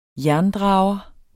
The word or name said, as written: jerndrager